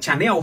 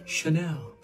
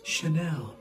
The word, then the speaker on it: Chanel: năm thương hiệu thời trang nổi tiếng mọi người hay phát âm sai hãng thứ nhất đừng đọc là chanel hay là chá leo các bạn hãy đổi chữ chờ thành chữ chờ và đọc một cách hờ hững một chút chanel chanel hãng